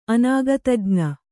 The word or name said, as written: ಅನಾಗತಜ್ಞ